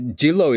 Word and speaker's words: Jinro: Sorry, Jillo is the Korean pronunciation of Jinro, J-I-N-R-O, which is one of the largest spirits manufacturers in the world.